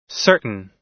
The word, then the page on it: certain: American English